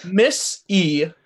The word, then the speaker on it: missiology: I don't know how to pronounce that word. – Miss-e-ology. We're going to study Miss-e.